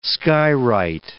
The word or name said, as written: skywrite